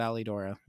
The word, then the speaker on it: Alidoro: I'm not gonna say the name of it because it's already too many people already go, but it rhymes with Pomodoro, and it's called Alidoro.